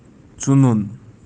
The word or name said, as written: tsunun